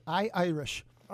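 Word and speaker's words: iIrish: Say it, I-Irish. – I-Irish. – And the first I is a little I, like digital.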